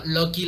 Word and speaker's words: Lucky: Y no, no Loki de, del dios Loki, sino Loki la pronunciación de suertudo. L-U-C-K-Y.